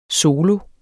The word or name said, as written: solo